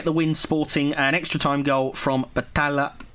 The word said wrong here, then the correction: Battaglia: Battaglia, I'll pronounce his name properly in the good header.